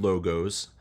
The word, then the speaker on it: logos: So in Greek, there is a word logos, L-O-G-O-S. Looks like the word logos, but it's pronounced logos.